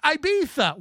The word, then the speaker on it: Ibiza: It's actually pronounced Ibiza.